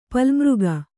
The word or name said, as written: ಪಲ್ಮಿಗ